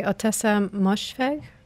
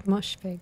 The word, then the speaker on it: Ottessa Moshfegh: Ottessa Moshfegh. – Okay. – Moshfegh.